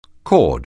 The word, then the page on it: cord: anglais